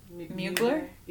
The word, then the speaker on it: Mugler: Mugler? – Yeah. – Terry Mugler.